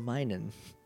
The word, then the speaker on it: Minun: It's minus, so Minun.